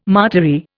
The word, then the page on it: martyry: Indian